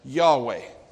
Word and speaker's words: Yahweh: Now, in Hebrew, the name of God is spelled YHWH. Because, well, the Hebrew, when it's written, they don't have vowels. But most scholars pronounce it as Yahweh.